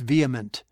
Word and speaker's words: vehement: No, it's vehement.